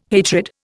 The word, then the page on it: hatred: Indian